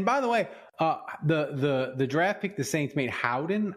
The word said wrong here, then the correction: Bowden: I think is how you say his name. Check your list. Check your list. – No, Bowden. – Is it Bowden?